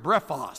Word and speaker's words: brephos: It's an interesting Greek word, brephos. Sounds like the word breakfast, B-R-E-P-H-O-S.